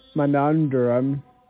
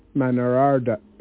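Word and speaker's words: Menander: Many Gnostic teachers of the first century, such as Simeon Magus, Monandrum, I'm pretty sure I'm saying that wrong. Okay, let me say that again. Simeon Magus, Menarder, and Centurthius, who all studied at Alexandria, Egypt, later became Christians.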